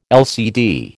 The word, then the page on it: LCD: American English